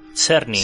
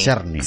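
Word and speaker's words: Czerny: que está interpretado por Henry ¿Cómo se dice esto? – Cerny. – Cerny.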